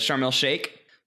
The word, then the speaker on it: Sharm el Sheikh: which I want to pronounce chic, a la Smash Brothers and Ocarina of Time, but Sharm el Sheikh.